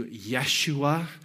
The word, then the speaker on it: Yeshua: Jesus is our anglicized sort of term for it. In the Hebrew, it would have been pronounced closer to Yeshua, a name that means God is salvation.